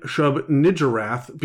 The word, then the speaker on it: Shub-Niggurath: Now, for those of you who are screaming at the radio, I am pronouncing it Shub-Niggurath because I'm not a horrible person.